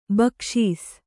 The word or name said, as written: ಭಿಕ್ಷಿಸು